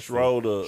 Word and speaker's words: Schroeder: He don't never say his name right. – This is how he said it. – Schroeder. – Schroeder.